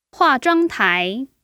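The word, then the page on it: huazhuangtai: hua4zhuang1tai2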